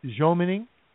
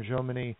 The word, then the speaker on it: Jomini: Most Americans will pronounce it Jomini or Jomini.